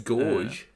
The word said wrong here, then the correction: Gauge: It means that I don't know how to pronounce certain words, and I always used to pronounce Gage Gorge, because the AU sound should make an OR sound linguistically, yet because English is a made-up language with five different languages stacked on top of each other, Gorge is not the correct pronunciation, and Gage is instead the correct pronunciation.